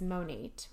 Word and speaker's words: Monat: So the name of the company is Monate, which stands for Modern Nature, which I think is really cool. It's spelled M-O-N-A-T, which it's funny.